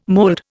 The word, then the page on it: mold: Indian